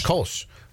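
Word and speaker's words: Kölsch: Kolsch.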